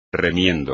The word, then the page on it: remiendo: espagnol